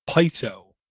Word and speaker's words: PISO: I believe it's pronounced PISO, P-I-S-O.